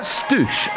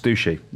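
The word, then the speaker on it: stushie: Is that Stoosh or Stushy? – That's pronounced Stushy.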